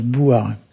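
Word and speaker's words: bouzar: Bouzard, on prononce bouard.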